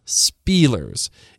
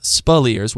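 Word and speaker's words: Speleers: And by the way, his name is Spilliers, not Spielers. It's pronounced Spilliers with the emphasis on the second syllable.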